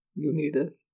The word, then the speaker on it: Unitas: Irsay traded quarterback Unitas. I don't know if that's how you say it. – Unitas.